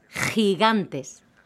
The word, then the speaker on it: gigantes: Los pandas gigantes. – Oh, But there's no J. – When the letter G is followed by an I or an E, it's pronounced like a J or J in Spanish.